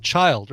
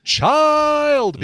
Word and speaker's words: Chiiild: Synthetic Soul is going to be the debut EP by a band called Child. Or maybe it's pronounced Child because it has three eyes. A three-eyed child is what this name of this band name is. C-H-I-I-I-L-D.